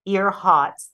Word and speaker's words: Earhots: They're called the Amelia Earhots. That they pronounce it the way one would pronounce it down here. It's a takeoff on Amelia Earhart. But because of the Louisiana accent, they call it the ear hot.